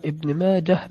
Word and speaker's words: ابن ماجه: بحسب ما أعلم ابن ماجه بالهاء، فتنطق بالهاء وصل ووقف أكثرهم على الهاء نعم.